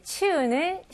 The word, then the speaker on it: chew: 그래서 치옷에다가 U만 놓으면 조금 부족해요. 거기다가 살짝 입술로 W 발음을 조금 주시면 좋아요. 그래서 그냥 chew가 아니고 chew.